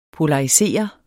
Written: polarisere

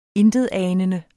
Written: INTETANENDE

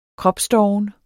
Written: kropsdoven

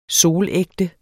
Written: solægte

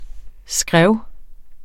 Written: skræv

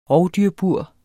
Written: rovdyrbur

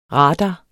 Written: radar

